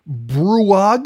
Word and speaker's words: BrUOG: Group, BRUOG. Did I say that right? – Correct.